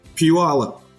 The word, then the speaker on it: Puyallup: Puyallup. – Puyallup. Get out of here. – Puyallup, Washington. – But it's spelled P-U-Y-A-L-L-U-P, yes? – Yes, it is.